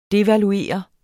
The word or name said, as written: devaluere